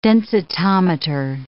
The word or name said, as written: densitometer